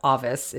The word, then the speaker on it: avis: We have the root avis, A-V-I-S, pronounced avis in Latin, avis,